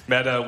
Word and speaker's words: metta: So this sort of activity, this confession and absolution activity, is rooted in a practice called metta.